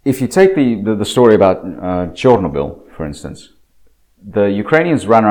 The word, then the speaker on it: Chernobyl: Chernobyl, actually, that's the correct pronunciation, Chernobyl.